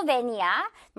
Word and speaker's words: van: 이거 우리 한국말로는 그냥 벤이라고 보통 하기 때문에 이거 벤이라고 하면 안 되고요. 토끼 이빨, 그죠? 앞니 보여주면서 이 V 발음 살려서 van이라고 해줄게요. van. Alright? 예문 볼게요. 저거 누구 van이야?